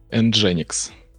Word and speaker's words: Nginx: Как, кстати, правильно произносить, а то опять скажу Nginx. – Nginx. – Nginx, все запомнили.